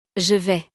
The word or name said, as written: je vais